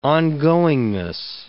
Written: ongoingness

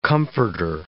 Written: comforter

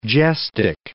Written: gestic

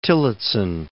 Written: Tillotson

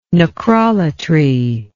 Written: necrolatry

